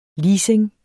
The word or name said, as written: leasing